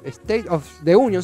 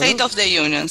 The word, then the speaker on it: State of the Union: Eh, estuviste cubriendo el, famoso SOTU, que sería, lo digo bien, State of the Union, no, State of the Union, sí, está bien, es, es eso. – State of the Union, sí.